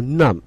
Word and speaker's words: numb: 对，用一个词 numb， N-U-M-B。 – 对，这个 B 是不发音的，对吧？ – 对的，一定要小心。